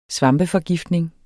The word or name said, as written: svampeforgiftning